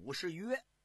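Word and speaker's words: 武士彟: 咱们书接前文，前文书啊就给您说的武则天的爹，咱们在这儿不是给您更正，老百姓啊把这个字啊念白了，念成个武士群，应该那个字啊正音念武士约，这还不是个多音字，这就等于是老百姓啊一错就错了，就如同啊您看，我给您啊举个例子，比方说啊这个碉堡的堡，这就念堡。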